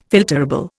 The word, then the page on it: filterable: Indian